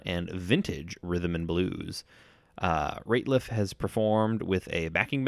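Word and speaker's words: Rateliff: Ratliff. Yeah, I think it's falsely pronounced Ratliff a lot, but it's definitely Ratliff.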